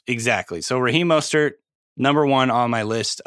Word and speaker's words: Raheem Mostert: Sorry, Raheem Mostert that's how you pronounce it.